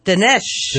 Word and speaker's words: Dinesh: Dinesh.